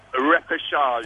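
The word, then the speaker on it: repêchage: Can you just explain to me, you said, how do you say, reportage? Can you explain that? – Repechage. – Repechage.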